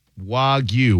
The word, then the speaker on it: Wagyu: Wagyu with a clear Y sound at the end. Wagyu. The word combines wa, Japanese, and gyu, beef cow, making the final syllable rhyme with sounds like view or cue rather than ending in a simple goo.